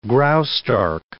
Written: Graustark

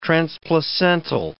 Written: transplacental

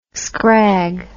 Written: skrag

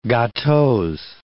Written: gateaux

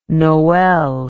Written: nowel

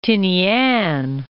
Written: Tinian

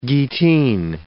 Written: gittin

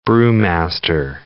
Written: brewmaster

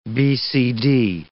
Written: BCD

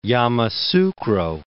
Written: Yamoussoukro

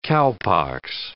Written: cowpox

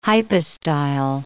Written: hypostyle

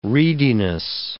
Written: reediness

